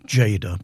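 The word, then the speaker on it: Tjader: It's pronounced Jada, but it's spelled with a T. T-J-A-D-E-R.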